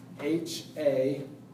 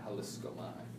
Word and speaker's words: haliskomai: Holiskomai is Haliskomai. Haliskomai.